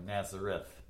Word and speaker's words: Nazareth: It's spelled like Nazareth, but it's pronounced Nazare.